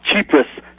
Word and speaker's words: Tsipras: Yeah, well, let me pronounce his name for you, Bob. Tsipras.